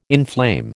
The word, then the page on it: inflame: American English